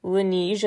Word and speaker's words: Laneige: There's a new BTS cosmetics line out that features three different lip-sleeping mask products, they're called, with Laneige, I believe it's pronounced, L-A-N-E-I-G-E.